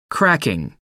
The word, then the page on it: cracking: American English